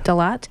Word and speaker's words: Dalat: It's called Lost in Dalat. Am I saying that right? – That's right. – Dalat.